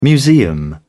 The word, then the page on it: museum: British English